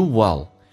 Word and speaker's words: will: 那么你肯定，你一定能够达成你的人生的目标。来看一下 you will. Will 特别难发，首先是 I 短元音，以嗓子眼为发力方向，声音下沉， I 往后靠一点点，舌尖太抬起来，靠上你的上齿龈，叫 oh, will, will.